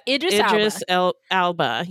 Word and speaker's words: Idris Elba: And the djinn is played by, I can never remember how his name is pronounced, Idris Elba? – Oh, yeah. Idris Elba.